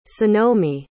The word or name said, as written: sonomi